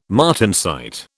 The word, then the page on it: martensite: British English